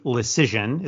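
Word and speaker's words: Leschyshyn: We can talk about the move the Rangers made today, which was to claim from the Vegas Golden Knights, Jake Lecision is how you pronounce it.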